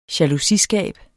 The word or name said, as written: jalousiskab